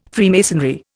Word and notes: Freemasonry: Indian

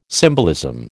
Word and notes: symbolism: American English

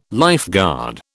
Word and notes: lifeguard: British English

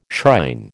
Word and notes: shrine: American English

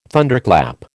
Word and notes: Thunderclap: American English

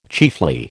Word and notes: chiefly: American English

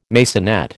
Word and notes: maisonette: American English

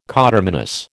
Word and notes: coterminous: American English

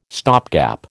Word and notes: stopgap: American English

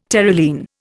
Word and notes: Terylene: Indian

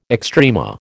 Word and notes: extrema: American English

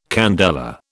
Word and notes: candela: British English

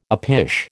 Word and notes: uppish: American English

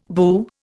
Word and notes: boo: Indian

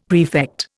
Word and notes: prefect: Indian

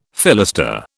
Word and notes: fillister: British English

